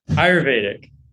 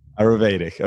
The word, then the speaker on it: Ayurvedic: He is an Ayurvedic doctor, and I probably butchered saying that. Did I say that right? – Ayurvedic. – Ayurvedic, okay. – You had it pretty good.